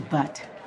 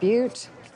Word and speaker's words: Butte: I like a ticket to Butt. – I believe it's pronounced Butte.